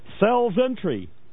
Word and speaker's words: Celsentri: and so it's called cells entry. Get it? Entering the cells. It is spelled CELSENTRI. Oh God, that's clever. Cells entry.